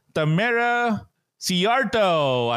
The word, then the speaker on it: Tamera Szijarto: So, let's welcome to the show, Miss Tamera Szijarto. I hope I pronounced that right. – Good job.